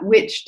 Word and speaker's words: wych: So spelled like that, witch, W-Y-C-H. But then there's a play on just the other word that we use, witch, W-H-I-C-H.